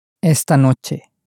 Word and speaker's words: esta noche: Esta noche. – Esta noche. – So, remembering that this is for Esther, you'll get a close enough pronunciation.